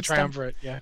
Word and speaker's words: Triumvirate: And back on Argus, I think out of the Triumvirate, is that how you pronounce that? – Yeah, the Triumvirate, Thank you.